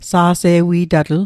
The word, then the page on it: sa'se'wi'tatl: Mi'gmaq